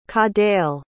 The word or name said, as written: cadell